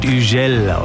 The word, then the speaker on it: Hugel: Das ist ein französischer DJ, deswegen heißt er nicht Hugel, so wird er nämlich geschrieben. Er wird Hugel ausgesprochen und schon klingt es viel cooler.